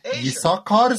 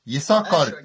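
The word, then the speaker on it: Issakar: Av Isakar stamme, 12.000. Isakar, uttales det, tror jeg.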